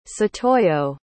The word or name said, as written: satoyo